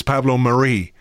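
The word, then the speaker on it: Marí: Well, he's got the accent is on the end. It's on the I, so it would lead you to believe it's Pablo Mari.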